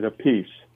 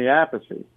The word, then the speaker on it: Appice: And then when he played with Rod Stewart, Rod Stewart pronounced it a piece. He said, that's easier, whatever. So he became Carmine a piece, and I stayed with Vinny Appice. And we have an older brother, Frank, and he pronounces it a peach, like the Italian way. – Yeah. – So there's three different ways to say the name, and it gets a bit confusing to everybody.